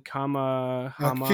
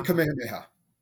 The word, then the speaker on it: Kamehameha: It's King Kamehameha. – King Kamehameha.